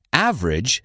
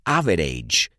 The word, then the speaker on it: average: Average significa promedio, media o corriente. No se pronuncia average, sino average, y rima con bridge.